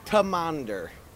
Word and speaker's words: Teh Monitor: Yeah, T-E-H Monitor. I'm standing here with half of Teh Monitor.